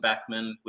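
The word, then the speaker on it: Beckmann: Beckman with two N's, the second N is silent, and you'll find me there.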